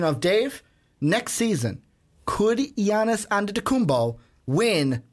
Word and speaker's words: Giannis Antetokounmpo: See, I didn't do the hard G. Yep. I didn't give you the Giannis. No, it's Giannis Antetokounmpo.